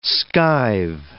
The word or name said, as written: skive